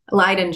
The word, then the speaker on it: Leyden: Am I saying that right? Leyden? – Yeah. Leyden jar.